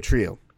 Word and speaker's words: Trujillo: It's really easy. You just go Trio.